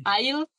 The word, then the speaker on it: Isles: Isles, como de pasillo de supermercado, Isle. Isle, esa es la pronunciación, ¿verdad? – Como isla.